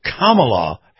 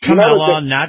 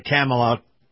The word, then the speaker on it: Kamala: It's Kamala, is how it goes? – Kamala. – Okay. – Not Kamala, Kamala, Kamala. – There was a professional wrestler back in the 1980s named King Kong Kamala.